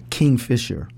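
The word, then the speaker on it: Kingfisher: We play every last Friday at Fisher King. Did I say that right? King Fisher. It's the King Fisher Waterfront Pub out in Maple Ridge.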